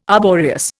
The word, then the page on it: arboreous: Indian